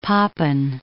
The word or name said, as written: Papen